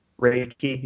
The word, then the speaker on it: Reiki: I've always Reiki.